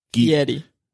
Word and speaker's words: Fieri: Obviously, he's Guy Fieri. – Okay. – And I think I'm pronouncing his name wrong. It's like Fieri.